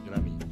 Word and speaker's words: Grammy: Grammy. Venga, muy bien pronunciado.